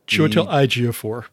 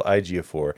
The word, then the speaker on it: Ejiofor: It's got Emilia Clarke, and you'll have to forgive me for this poor pronunciation, Chiwetel Ejiofor. – Chiwetel Ejiofor.